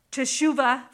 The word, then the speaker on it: Teshuvah: Teshuvah, thank you.